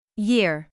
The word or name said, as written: year